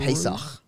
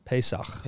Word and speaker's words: Pesach: Pesach. – Pesach.